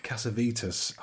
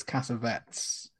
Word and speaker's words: Cassavetes: And then it's and there's the flashback with Nick Cassavetes, hopefully I'm pronouncing correctly. They're reunited for the first time since. – I think it's Cassavetes. – Cassavetes? – Like cassettes, isn't it? – Cassavetes.